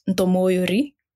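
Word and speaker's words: M'Munyori: But so my last name should actually be pronounced Ntomoyori, which means one who is filled or full.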